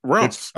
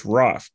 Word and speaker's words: Raph: No L in Raph. – Raph? I thought it was Ralph. I thought his nickname's Ralph. – No, it's Raph. It's Raphael, so it's Raph.